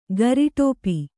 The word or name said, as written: ಗರಿಟೋಪಿ